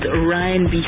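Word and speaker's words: Raï: Not R&B, but Ryan B, right? As in it's spelled R-A-I, and then the I has got like two little dots on top of it, and then B, like R&B part, right?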